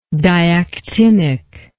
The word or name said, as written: diactinism